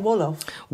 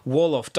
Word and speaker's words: Wolof: Wolof. – Wolof. W-O-L-O-F.